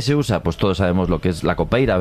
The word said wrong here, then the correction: capoeira: ¿verdad? – Capoeira. – Gemala. Qué mal pronuncio últimamente.